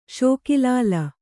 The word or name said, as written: ಷೋಕಿಲಾಲ